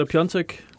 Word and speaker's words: Piontek: Men uh, ja, nej, men det, er, det er sådan, man udtaler Pjontek, ligesom uh, vores men gode gamle vi gøre det?